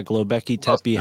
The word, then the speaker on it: Göbekli Tepe: And I want to say there was some similar hieroglyphics at Göbekli Tepe. Is that how you pronounce that? – Yeah. Göbekli Tepe has animals on it that are undiscovered by science.